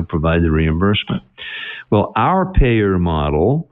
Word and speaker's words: PAEER: And actually, in the book, we've created a model, and the acronym for the model is PAEER, 5 letters, pronounced payer.